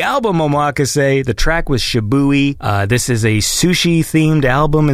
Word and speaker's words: Shibui: So her favorite track is Shibui. Is that how you say that word? – Yeah, I would agree that's how you say it, Shibui.